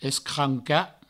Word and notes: escrancar: provençal; escranca

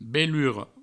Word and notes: belura: Provençal; beluro

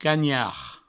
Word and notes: canhard: provençal; cagnard

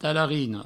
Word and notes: talarina: provençal; talarino

